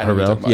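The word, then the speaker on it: Har'el: And it was also, I know I'm going to butcher her name, but Alma Harrell? – Yes, yeah. – Harrell? Yeah. So she directed Honey Boy.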